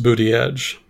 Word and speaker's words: Buttigieg: Well, I think it's pronounced Booty Edge, but I guess I could be wrong.